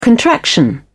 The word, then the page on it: contraction: British English